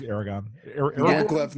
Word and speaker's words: Aragorn: He's Aragorn.